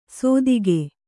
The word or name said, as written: ಸೋದಿಗೆ